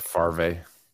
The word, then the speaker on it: Favre: Brett Favre. Favre. – Why is it spelled like that? I always got confused with that. So weird. – No, because you're used to it being Farve instead of Favre.